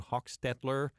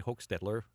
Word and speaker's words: Hochstetler: A new study you did together with a colleague, Andrew Hochstetler. Maybe I'm giving it too much of a German pronunciation there. You probably say Hochstetler.